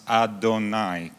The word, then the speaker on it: Adonai: And if you see, for example, this, Yahonai, like this, then it tells you that it should be pronounced Adonai, because it's A-O-A.